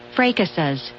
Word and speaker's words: fracas: So it is, in fact, pronounced fracas.